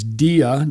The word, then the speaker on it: dia: Dia is like our word diameter, like a line running through a circle, comes from dia, and we all came out of God.